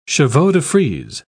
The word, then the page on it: chevaux-de-frise: British English